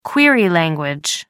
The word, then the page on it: query language: American English